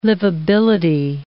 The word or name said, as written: liveability